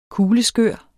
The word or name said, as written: kugleskør